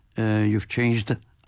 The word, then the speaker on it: You've Changed: El tema lento, balada, como he dicho, el tema de Carl Fisher, You've Changed, You've Changed, hay que pronunciar la D, sí, que se note, has cambiado.